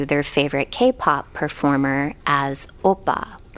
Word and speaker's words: oppa: So it's pronounced Oppa? Oppa? – Oppa.